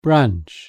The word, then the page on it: branch: British English